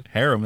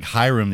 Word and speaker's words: Harem: How do you pronounce that thing? – Harem. I thought that was very good. – Thank you. – It came out like Hiram the other day.